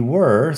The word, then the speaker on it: were: Fíjate primero en la pronunciación de were. Were que no es igual que where. ¿De dónde? Es were.